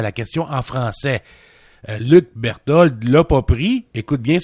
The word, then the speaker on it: Berthold: C'est comme ça qu'on le prononce, hein, Berthold? – Oui, c'est ça.